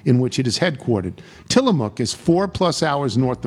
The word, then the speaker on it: Tillamook: The mook part is pronounced like cook, Tillamook.